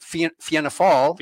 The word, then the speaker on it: Fianna Fáil: He is joining the other two parties, Fine Gael and Fianna Fáil. Did I pronounce them right? – Fianna Fáil.